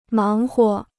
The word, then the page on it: 忙活: máng huo